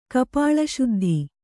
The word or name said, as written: ಕಪಾಳಶುದ್ಧಿ